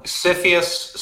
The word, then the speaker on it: CFIUS: Okay, so CFIUS sounds like syphilis, just as easy to catch, just as hard to get rid of.